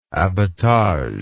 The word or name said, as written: abattage